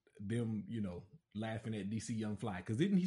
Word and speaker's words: DC Young Fly: And those dudes, so is it DC Young Fly? – Uh-huh.